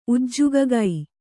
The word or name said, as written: ಉಜ್ಜುಗಗೈ